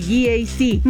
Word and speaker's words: GAC: No se dice GAC, se dice GAC, nuevamente GAC, y se maneja mejor de lo que se pronuncia.